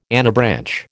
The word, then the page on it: anabranch: American English